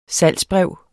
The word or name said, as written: salgsbrev